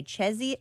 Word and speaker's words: Czechia: At 8, again, these are central times, how do you say Czechia? – Czechia? – C-Z-E-C-H-I-A. Czechia? – Czechia.